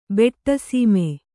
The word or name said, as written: ಬೆಟ್ಟಸೀಮೆ